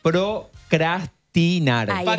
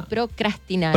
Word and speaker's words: procrastinar: Procrastinar. – Es con R. Adelante, chico, es las dos veces con R. Procrastinar.